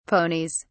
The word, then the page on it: ponies: British English